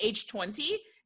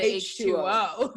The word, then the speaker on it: H2O: H2O. Because they told us not to pronounce it H20. They said pronounce it H2O. They were very adamant about that.